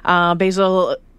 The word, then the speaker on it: Bassel: One of the first Syrian refugees to make their way to Edmonton, Basil. I'm saying it wrong. – No, it's right.